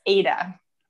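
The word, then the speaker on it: Aita: I'm like, oh my gosh, I've been saying it wrong for 2 years, but it's Aida.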